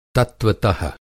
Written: तत्त्वत:

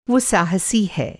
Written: साहसी

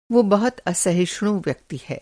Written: असहिष्णु

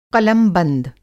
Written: क़लमबंद

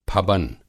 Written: फबन

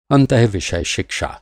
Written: अंतर्विषय

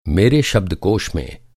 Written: शब्दकोष

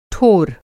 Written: ठोर

